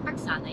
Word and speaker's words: Oxana: Oxana, yeah.